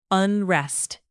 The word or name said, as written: unrest